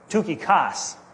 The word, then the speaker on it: Tychicus: I looked at the Greek word for Tychicus, and it's pronounced the way I've learned to pronounce it, Tychikos.